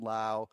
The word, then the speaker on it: Lowe: Brandon Lowe. Uh, got that pronunciation right, batting eighth and playing second base.